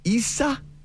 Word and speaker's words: Isaias: So the pronunciation is, if you want to do like a phonetic spelling out for yourself, like for those keeping score at home, it's Isaias. Yes.